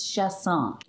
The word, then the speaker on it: Chaisson: So how do you say your last name? – You know, I answer to anything. You know, having a name that is spelled the way it is, I get used to whatever anyone wants to call me. So I've been called a little bit of everything, but it is Chaisson is the correct pronunciation. – Chaisson as French? – Yes, French via Louisiana.